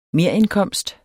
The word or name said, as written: merindkomst